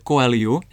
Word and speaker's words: Coelho: A je to totiž novinka Paula Coelha, který se správně vyslovuje Coelho, jak jsem se dozvěděl, protože to je Brazilec.